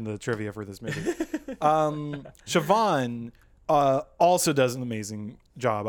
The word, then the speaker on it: Siobhan: It's Siobhan. – Oh, well, it's okay. Yeah. So it's pronounced much different than how it's written.